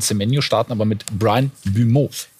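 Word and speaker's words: Mbeumo: Brian, ausgesprochen Bumeau, das ist ein Flügelspieler, richtig spannender Mann von Brentford.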